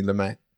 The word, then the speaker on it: Lumet: I wanted to touch on Sidney Lumet. Is it? Do you pronounce a T? – I do, but you know. – We'll add the T there.